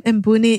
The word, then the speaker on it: Mbouni: So my last name, Mbouni, is spelled M-B as in boy, O-U-N as in Nancy, I.